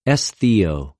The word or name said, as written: ἐσθιω